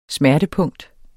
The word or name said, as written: smertepunkt